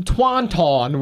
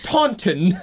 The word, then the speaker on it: Taunton: Not in Taunton, which is how I originally thought to pronounce it. It's Taunton.